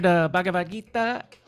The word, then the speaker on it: Bhagavad Gita: Well, we covered Bhagavad Gita. I said it right, yeah? – Yeah, that was good.